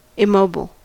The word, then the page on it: immobile: American English